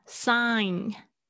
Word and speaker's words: sign: Euro exchange rate exchange 这个也是很轻的哈，exchange rate 汇率 exchange rate small small change 很轻的 sign sign 那个嗯的声音 G 不发音签名 signature signature 特别容易错的这个字 signature ATM Automatic teller machine，自动提款机。